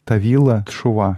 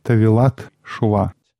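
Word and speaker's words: Тавилат Шува (טבילת תשובה): Я всегда раньше думал, что нужно говорить Тавила Чува, но, судя по всему, нужно тех как бы присоединять к первому слову. Поэтому правильно произносить Тавилат Шува.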